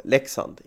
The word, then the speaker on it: Leksand: Men uttalet är ju då Leksand, inte Leksand. Och så.